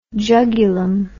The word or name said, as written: jugulum